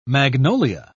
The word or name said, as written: magnolia